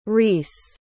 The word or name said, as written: reis